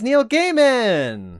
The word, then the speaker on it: Neil Gaiman: It is Neil Gaiman! – Mm-hmm. – Good job. – Yeah, we pronounce his name right.